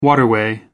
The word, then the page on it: waterway: American English